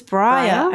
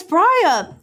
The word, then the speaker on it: Briar: It's Briar. It's Briar, I'm pretty sure. – Briar like Mariah? – Yeah.